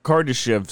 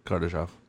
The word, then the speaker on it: Kardashev: Now, to to expanding lasting life is measured by the Kardashev scale. Kardashev. Kardashev. I didn't know I was going to say that wrong. Um, the Kardashev scale.